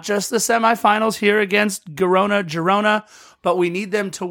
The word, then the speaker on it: Girona: Against Girona, and I'm probably mispronouncing that, right? – Girona. – There you go.